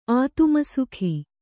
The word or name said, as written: ಆತುಮಸುಖಿ